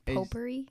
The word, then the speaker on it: potpourri: Potpourri? – Yeah, potpourri. I think you spell it POTPOURRI or something. I think. – POTP? – Yeah, POTP. – Oh, so the T is silent.